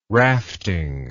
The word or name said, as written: rafting